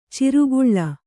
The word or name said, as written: ಚಾರ್ಕಲ